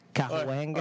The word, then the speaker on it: Cahuenga: When we were pronouncing Cahuenga, Cahuenga? – Cahuenga.